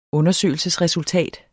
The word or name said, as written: undersøgelsesresultat